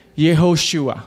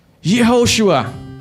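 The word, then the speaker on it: Yehoshua (יהושע): Now, interestingly, these two names, Joshua and Jesus, are pronounced in Hebrew Yehoshua. Yehoshua. The same.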